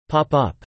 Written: pop up

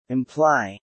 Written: imply

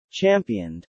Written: championed

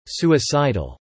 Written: Suicidal

